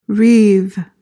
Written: reeve

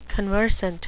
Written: conversant

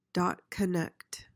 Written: dot-connect

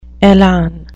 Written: elan

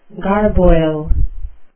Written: garboil

